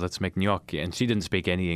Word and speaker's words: gnocchi: G-N-O-C-C-H-I, by the way. Gnocchi is how you pronounce that word.